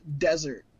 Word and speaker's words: Dessert: It's spelt dessert, but it's pronounced desert. So that makes it better. It's Buddy Desert, which is way better.